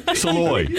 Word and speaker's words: Salloy: A Shallowy as well. Keenan! Shallowy! – Somebody's got to tell this kid how to pronounce his name. Saloy. S-A-L-L-O-Y. Saloy.